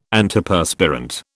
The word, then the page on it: Antiperspirant: British English